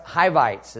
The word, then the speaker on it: Hivites: And the seven nations, they're actually pronounced Hivites is how that's pronounced.